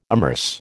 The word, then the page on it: immerse: American English